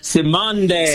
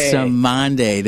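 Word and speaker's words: Cymande: Simonde. – Simonde.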